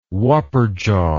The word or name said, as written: wapperjaw